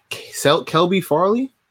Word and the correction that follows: Caleb Farley: Caleb Farley. I'm not sure if I'm pronouncing that right. – Yeah, Caleb. – Caleb Farley. Yep, cornerback.